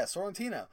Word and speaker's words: Sorrentino: It's the new Jeff Lemire and Andrea Sorrentino. – Cool. – Did I say that right? Yeah, Sorrentino.